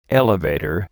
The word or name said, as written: elevator